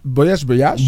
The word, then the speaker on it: voyage: ¿Cómo se dice en francés? – Es voyage.